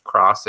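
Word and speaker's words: Etrian Odyssey X: It looks like an X, but it is pronounced Cross. It even says Cross in the graphic.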